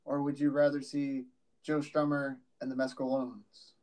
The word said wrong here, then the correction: Mescaleros: Mescaleros. – Mescaleros.